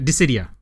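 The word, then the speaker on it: Dissidia: Not Disgaea, Disidia. Oh, horrible.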